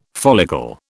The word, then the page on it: follicle: British English